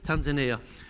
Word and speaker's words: Tanzania: I was in Africa, I was in India, I was in Tanzania, how do you pronounce it? Tanzania.